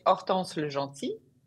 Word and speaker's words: Hortense Le Gentil: So it's Hortense, in French you say Hortense Le Gentil, but in America you can say Hortense Le Gentil.